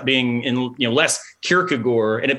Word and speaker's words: Kierkegaard: So yeah, in Danish, the A-A-R-D is sort of swallowed. So you end up much more in a Kierkegaard.